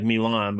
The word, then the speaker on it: Milan: It's spelled like Milan, but they pronounce it Milan.